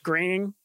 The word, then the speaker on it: Groening: Yeah, I believe it's pronounced Groening. I only say Groening.